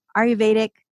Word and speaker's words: Ayurvedic: And Julie's also the author of both Nourishing Newborn Mothers and Ayurvedic, how do you say that? Sorry. – Yeah, that was right. – Oh, God, I was close.